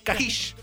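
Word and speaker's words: Kashyyyk: Se pronuncia Kahish.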